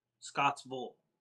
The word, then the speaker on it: Scottsville: Vole, not Beale. – Scottsville. Oh, that Ville? Vole? – It's spelled the same as Beale, but we don't say it that way.